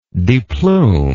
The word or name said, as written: deplumation